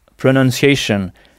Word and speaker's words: pronunciation: Pronunciation, qui s'écrit avec UN et non pas ON comme en français. Donc, PRONUNCIATION, qu'on prononce pronon, pronunciation. Donc, on frappe la syllabe qui vient juste avant la terminaison shun.